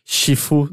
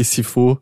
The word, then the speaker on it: Sifu: Sim, sabemos que a pronúncia é muito mais Shifu do que Sifu.